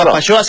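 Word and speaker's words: Apapaxoa: Entonces, la invitación abierta a Papashoa, se escribe con X, Juan, ¿eh? A claro. Papashoa, se pronuncia como si fueras de Chihuahua, pero es a Papashoa.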